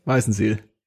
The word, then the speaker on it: Weißenseel: ist Weißenseel. Und der wird so ausgesprochen, wie ich ihn ausspreche. Weißenseel.